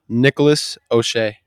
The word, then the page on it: Nick O'Shea: male